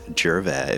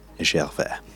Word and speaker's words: Gervais: So mine, it's Brent Gervais, if you want the, that's kind of the English pronunciation of it, but if you want it with the French Canadian pronunciation, it would be Brent Gervais.